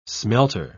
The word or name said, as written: smelter